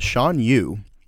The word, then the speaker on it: Shan Yu: So I would say that my second top villain, and correct me if I mistake the pronunciation, but Shan Yu, the antagonist of Mulan.